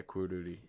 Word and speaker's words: Quruli: And then another band is I can never say it, Quiruli. It's spelled Q-U-R-U-L-I, and their main song is Tokyo, and it's fucking awesome.